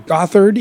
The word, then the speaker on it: Gothard: That's right, the Celtic pronunciation is G-A-W-T-H-I-R-D. Gothard, even though it's spelled Gothard.